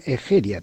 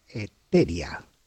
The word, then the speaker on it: Egeria: Una la tomo de una escritora gallega importantísima que se llama Egeria, también se pronuncia Eteria.